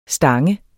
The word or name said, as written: stang